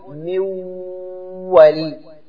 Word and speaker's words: من ولي: وهكذا من ولي تقول من ولي، لاحظ نطقنا ماذا الواو، من و الأول ساكنة والثانية متحركة، هذا الآن أصبح حرفا مشددا من